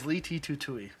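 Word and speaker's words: Lê Thị Thu Thủy: Leety Tutui. – Of course. – I had to learn Vietnamese pronunciation to pronounce that correctly. So it is Leety Tutui, which is the greatest name of a founder ever.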